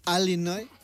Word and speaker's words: Illinois: ¿Sabes que hay, como aquí en España, hay acentos diferentes en Estados Unidos y los nativos del estado de Illinois lo pronuncian Illinois?